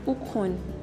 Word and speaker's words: Ukwun: Yeah, I'm Ukwun.